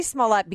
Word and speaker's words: Smollett: Not until Mike Scott read his name on the air during the newscast did I realize that it's the second syllable that is accented on Jussie Smollett, because I get all my news from Twitter, and I've read countless and tweeted endlessly about this myself.